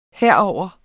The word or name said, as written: herover